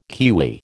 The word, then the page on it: kiwi: American English